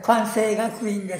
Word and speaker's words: 関西学院: 中で漢音読みで関西、まあ、当時は九安西と言っていたんですけれども、そういった流れの中で関西学院というふうに。 – 九安西からですか。 – はい、そうなんです。なるほど。 – 英語表記は今でも関西 K-A-N-S-E-I ではなくて、九安西 K-W-A-N-S-E-I というふうに表記するんです。 – そういうふうに発音される。 – あ、はい、そうなんです。 – いや、やっぱり関西学院が正解ですね。